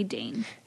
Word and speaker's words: Dain: a Dain. I think I'm saying his name right. He's going to be so mad if if I'm butchering. – It's definitely got to be Dain.